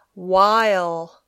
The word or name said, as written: while